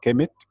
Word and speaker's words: Kemet: And to explain why I didn't write the vowels, like I pronounced the vowels, Kemet, E-K-E-M-E-T, because we are not very sure about the vowels when we read the Egyptian language. We are very sure about the consonant.